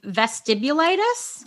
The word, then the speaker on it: vestibulitis: And there is another diagnosis called vestibulitis. I can never say this right. – What, is it vestibulitis? – Yes. There you go.